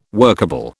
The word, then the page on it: workable: British English